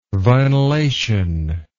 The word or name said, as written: vinylation